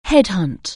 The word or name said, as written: headhunt